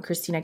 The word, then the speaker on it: Cristina: So you can find me there, Cristina Cataman.